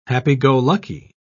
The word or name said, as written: happy-go-lucky